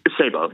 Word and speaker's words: Sabo: Sabo? – Sabo, yes.